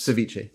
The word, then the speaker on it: ceviche: And the other thing was ceviche. Maybe I've made it once, but like my fear of ceviche is this. – Ceviche. There's no R.